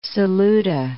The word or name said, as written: Saluda